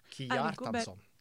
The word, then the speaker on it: Kjartansson: KJ-lyden finnes ikke I andre språk enn det norske. – Kjartansson. – K og J uttales separat, ja, med vennlig hilsen språk, Petimeter.